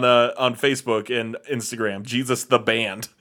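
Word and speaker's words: Geez's: It is spelled as written G E E Z apostrophe S, also known as Jesus.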